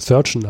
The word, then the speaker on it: Surgeon: Er war äh, Sergeant Lieutenant äh, ähm, bei der Royal Navy. Ähm, wobei ich das gerade, glaube ich, falsch ausgesprochen habe mit äh, Surgeon.